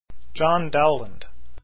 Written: dowland

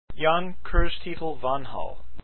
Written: Vanhal

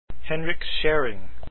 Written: Szering

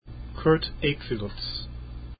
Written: equiluz

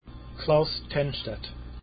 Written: Tennstedt

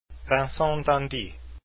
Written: d'indy

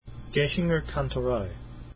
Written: gaechinger kantorei

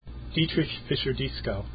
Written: fischer-dieskau

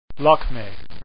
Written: lakme

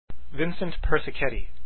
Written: persichetti

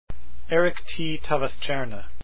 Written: Tawaststjerna

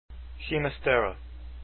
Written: ginastera

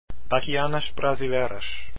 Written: bachianas

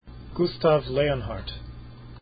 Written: leonhardt

